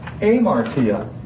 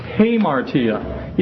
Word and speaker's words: hamartia: And actually it begins with an alpha, and you would think, well, that should be pronounced amartia. But it has a rough breathing mark over the beginning of it, if you were to look at it in the Greek text. And so now it has a H sound to it, so it's pronounced hamartia.